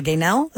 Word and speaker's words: Gaynell: G-A-Y-N-E-L. Gaynell, am I saying it right? – Yeah. – Okay. – Yes, you are.